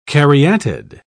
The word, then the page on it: caryatid: British English